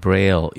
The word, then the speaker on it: Braille: 그래서 이것은 브라유라고도 읽는데요. 왜냐하면 LLE는 프랑스에서는 U라고 읽습니다. 그래서 이건 브라유식 방식의 점자를 말합니다. 또는 영어로 그냥 읽으면 Braille 이렇게 읽어도 됩니다.